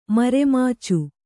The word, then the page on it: ಮರೆಮಾಚು: mare mācu